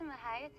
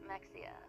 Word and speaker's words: Mexia: Welcome to Mahia, Texas, my hometown. Most people pronounce it Mexia, and it's not.